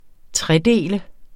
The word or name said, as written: Tredelte